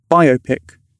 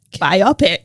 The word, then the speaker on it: biopic: Biopic. – Yes. That is the UK pronunciation according to the Cambridge Dictionary. And the US pronunciation is... – Biopic. – Biopic.